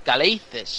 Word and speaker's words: Calathes: s'havia dit a la presentació de Nick Caleices, segons ell mateix es pronuncia així perquè ell és greco-americà i el nom, és, el nom es pronuncia el cognom almenys, perdó, en americà uh, què us va semblar la presentació de Caleices?